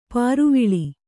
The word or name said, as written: ಪರಿವೇಲ